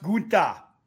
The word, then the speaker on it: Gunther: Yeah, some of them, you know, like Gunther, for example, will deliver the chop. – I believe it's pronounced Gunta.